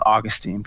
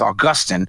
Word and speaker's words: Augustine: You know, he pronounces it the British way. I always thought it was Augustine, but he says Augustine, new temptation.